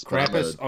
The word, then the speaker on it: Krampus: So we'll end it there, and we'll be back with, is it Krumpus or Krampus? How do you pronounce it? I think I pronounced it wrong. – Hell if I always just say Krampus. – Krampus, okay.